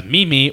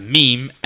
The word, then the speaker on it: meme: In its early days, meme, which incidentally is often mispronounced as meme or meme, but is in fact pronounced meme, M-E-E-M.